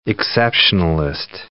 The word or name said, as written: exceptionalist